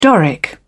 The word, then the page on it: doric: British English